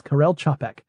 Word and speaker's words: Karel Čapek: Did you look up that pronunciation? – I did, in fact, listen to it a couple of times. Karel Čapek. Because his last name is spelled C-A-P-E-K, and it includes symbols that are not in the English alphabet. – Right. – Above, like squiggly lines and little UFOs and things. He wrote R-U-R, also known as Rossum's Universal Robots.